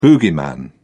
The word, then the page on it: boogeyman: British English